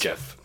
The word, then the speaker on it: Geoff: Jeff. – It's Jeff. – Jeff. No, that reads as Geoff. – It's pronounced Geoff. – It looks like Geoff, but it goes by Jeff. – How do you pronounce that if there's an E-R-Y at the end of it?